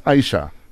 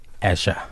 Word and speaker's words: Asha: This morning, we're hanging out with Joe Mabija from, is it pronounced Aisha? – Asha. – It's Asha. – Yeah. – A-S-H-A.